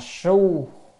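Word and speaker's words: xu: Phật đạo chi, chữ này đọc là su, su yếu giả.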